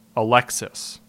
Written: Alexis

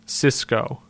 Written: Cisco

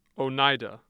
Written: oneida

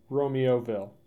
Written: Romeoville

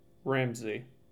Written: Ramsey

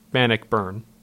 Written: Bannockburn

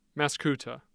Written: mascoutah

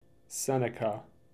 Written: Seneca

